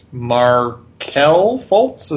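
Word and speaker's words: Markelle Fultz: and they picked Markel Fultz.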